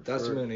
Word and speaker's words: Dosunmu: Dosunmu, yeah, yeah.